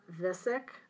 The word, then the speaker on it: Viskic: Well, I'm also reading And Fire Came Down by Emma Viskic. I think is how you pronounce her name mm-hmm. V I S K I C.